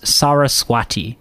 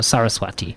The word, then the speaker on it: Saraswati: It's the celebration of the birthday of the goddess Saraswati. I believe that's how that's pronounced, or Saraswati, the goddess of knowledge and arts.